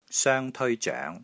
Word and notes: SOENG1 TEOI ZOENG2: Cantonese

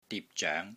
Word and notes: DIP6 ZOENG2: Cantonese

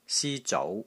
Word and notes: SI1 ZOU2: Cantonese

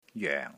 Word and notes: JOENG4: Cantonese

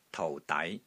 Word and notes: TOU4 DAI6: Cantonese

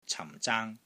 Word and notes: CAM4 ZAANG1: Cantonese

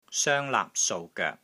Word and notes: SOENG1 LAAP3 SOU3 GOEK3: Cantonese

